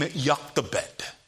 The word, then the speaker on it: Jochebed: You see, Moses' mother's name is found only two times in Scripture, that name Jochebed. That is how we would pronounce it, reading the word. The Jews would say Jochebed. The name Jochebed literally means Jehovah his glory.